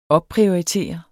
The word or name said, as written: opprioritere